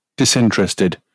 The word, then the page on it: disinterested: British English